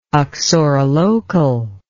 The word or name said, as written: uxorilocal